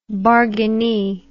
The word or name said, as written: bargainee